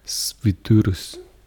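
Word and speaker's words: Švyturys: Svidurus. – Ja, og det er simpelthen så fantastisk, fordi det der Y, det udtales nemlig I på litauisk.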